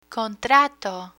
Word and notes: contrato: Spanish

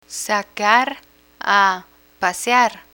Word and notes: sacar a pasear: spanish

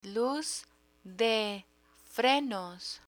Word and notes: luz de frenos: Spanish

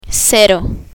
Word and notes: cero: spanish

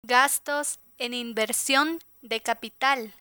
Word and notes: gastos en inversion de capital: Spanish

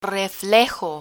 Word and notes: reflejo: Spanish

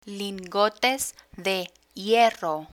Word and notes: lingotes de hierro: spanish

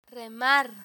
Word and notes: remar: Spanish

